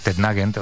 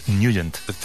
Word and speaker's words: Nugent: Hombre, siempre lo hemos llamado en el barrio Tednagent o Tednagent. – Claro, ¿no? Yo era de Tednagent. – Pero la pronunciación correcta es Nugent.